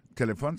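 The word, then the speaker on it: telephone: N'a pas remarqué que l'accent tonique l'a tombé sous premier syllabe là. Si nous besoin de dire bien, c'est téléphone.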